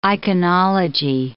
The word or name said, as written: iconology